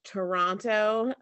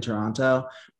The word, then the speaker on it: Toronto: Kelly gives me so much shit about over enunciating the second T in Toronto. And I'm like, because most people call it Toronto.